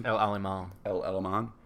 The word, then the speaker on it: El Alamein: Well, Sherman tanks, they were very successful in the British were using them very successfully in the Battle of Al Alamein. Am I saying that correctly? – El Alamein.